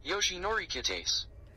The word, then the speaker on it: Yoshinori Kitase: Yoshinori Kitase.